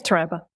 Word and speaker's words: Taraba: Did I say that correctly? Taraba? – Yes, Taraba.